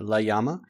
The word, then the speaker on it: Llama: It's spelled with a double L, and in Spanish that's Llama sound. So imagine it with a Y instead of two L's. So Llama.